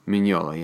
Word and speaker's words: Mignola: Is it pronounced Mignola? – Mignola, yeah, Mike Mignola.